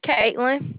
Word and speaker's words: Kaitlyn: Caitlin. – Would you spell that, please? – K-A-I-T-L-Y-N.